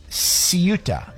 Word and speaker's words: Ceuta: Okay, let's go to Ciuta. Ceuta is how it's pronounced.